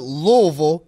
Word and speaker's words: Louisville: for you to you know represent the University of Louisville.